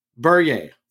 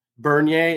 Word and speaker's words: Bernier: I had to phonetically spell this out. Bernier. – All right. – B-E-R-N-I-E-R. And I know it's a French name. I'm sure I'm going to get hammered on the French, but that's what I got.